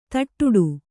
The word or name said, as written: ತಟ್ಟುಡು